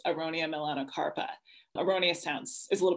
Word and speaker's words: Aronia: Am I pronouncing, is it Aronia? – Yeah, or? – Aronia.